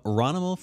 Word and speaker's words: Ronimal: The next email is from Ronimal from the ATL. And he says, think Danimal yogurt. But it's spelled Ronimal with an O-N.